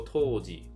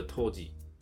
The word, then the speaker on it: 杜氏: 所谓的杜氏，杜就是这个呃一个姓氏，一个土，一个木啊，杜杜氏，那日文念作托吉，或念作短音的托吉，那在日文里面就是清酒的首席酿酒师的意思啊。